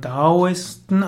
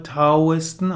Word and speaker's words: Daoisten: Korrekter spricht man Daoisten aus, aber es gibt auch die Aussprache Taoisten auch in China. Aber ich werde jetzt weiter von Daoisten sprechen, auch wenn es in diesem Artikel mit T geschrieben wird.